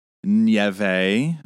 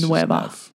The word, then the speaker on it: Neve: It's Neve Campbell. – Yeah. And for those of you who were out there with me, it's not pronounced Nieve. So it is. It's Nueva. – Nueva. Interesting.